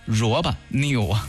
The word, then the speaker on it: Roboneo: 大家说你怎么读萝卜呢？明明是萝卜，你怎么日乐不分呢？因为它是英文 R-O-B-O-N-E-O，萝卜 New。